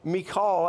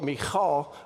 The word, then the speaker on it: Michal: And his wife, Michal, I think is the way you pronounce it in Hebrew, probably with a little more like Michal, she is upset about this.